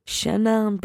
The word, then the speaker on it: Chenin: No. Actually pronounce the vowel. Don't just get rid of them. Don't just go chenin.